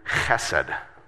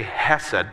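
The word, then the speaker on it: chesed (חֶסֶד): Um, it, it's the, the Hebrew word chesed. So say chesed, but kind of say it like you're kind of clear your throat, right?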